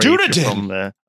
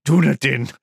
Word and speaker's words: Dunedin: The airport in the south of New Zealand at Dunedin, I don't know how to pronounce that place. Sorry. – Dunedin. – Dunedin.